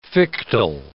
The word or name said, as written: fictile